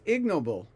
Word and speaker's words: ignoble: It's ignoble. It's not ignoble. The word is pronounced ignoble. I never realized that all my life. I've known that word all my life, but never knew it was pronounced ignoble. According to the dictionary that I have right now, anyway, which is the Oxford American, I think it is. Ignoble. The stress is on the first syllable.